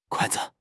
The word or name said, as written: kuai4 zi5